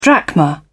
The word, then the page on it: drachma: British English